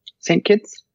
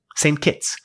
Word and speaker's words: St. Kitts: St. Kitts. – St. Kitts. – K-I-T-T-S, which is short for St. Christopher, but nobody says St. Christopher. They just say St. Kitts.